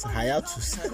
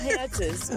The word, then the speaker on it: hiatus: That's how you pronounce that word. – Hiatus. – I thought it was hiatus. – No, it's just hiatus. What? – I'm so African.